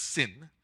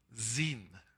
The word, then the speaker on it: Zin: It's not pronounced sin. It should be seen. And it should be a Z instead of a S. That's the original. It's the wilderness of Zin.